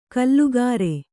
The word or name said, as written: ಕೂಲಿಕಾರ